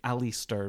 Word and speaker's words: Aleister: I say Alistair because it's spelt like A-L-E-I-S-T-E-R.